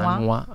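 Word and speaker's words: Manhua: Poi, se vale tutto, metto anche Manua, Manua? Ho detto bene? Manua? – Manua, Manua sì, Manua.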